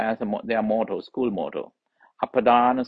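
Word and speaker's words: apadāna: Apadana sobhini, I'll explain it later. Panya, apadana, APADA, long A, NA, apadana. Next word is sobhini, SOBHINI, SOBHINI, sobhini.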